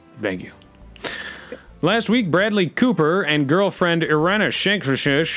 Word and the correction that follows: Irina Shayk: Irina Shankek, I think you're saying it right. – Irina Shayk ended their relationship after 4 years of dating, according to People.